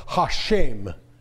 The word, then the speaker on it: Hashem: I've got a Hebrew Bible in my office that every time that it says the four-letter name of God, it's pronounced Hashem.